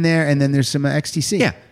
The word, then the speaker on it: XTC: Is it pronounced Ecstasy? Or is it XTC? – I think it's XTC. But I could be very wrong because I think it is XTC. – I think it's XTC too, but it's also ecstasy.